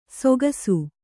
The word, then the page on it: ಸೊಗಸು: sogasu